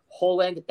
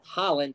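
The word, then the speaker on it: Højlund: Yeah, look, I mean, Hojlund, you don't pronounce the D. It's Hojlund.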